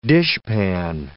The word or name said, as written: dishpan